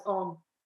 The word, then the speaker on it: Om: In Sanskrit grammar, A and U coalesce to form O. Thus, A, U, and M together is pronounced as Om.